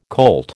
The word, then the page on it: colt: American English